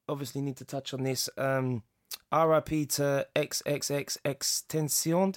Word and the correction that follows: XXXTentacion: Did I say that right? – XXXTentacion. – RIP. – Tentaf. – They're calling him. – You got to say it with a flat tongue. XXXTentacion.